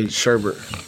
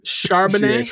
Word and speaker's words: Charbonnet: I just say Sherbert. – Like ice cream. – Charbonnet. – Something like that.